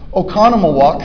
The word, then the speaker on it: Oconomowoc: He said, how do you pronounce that? That's Oconomowoc.